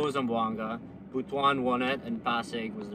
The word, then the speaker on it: Butuan: Runner up was, how do you say this? Butuan. – Butuan. – Yeah, Butuan was the runner up for the first one.